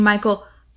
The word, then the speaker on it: Mykal: Okay, so one is literally spelled like Michael the White Way. – Yeah. – And then a bl- black woman named Michael M Y K A L kicks her adult daughter out of her home a heartfelt and surprising journey through womanhood, identity, and what it means to belong.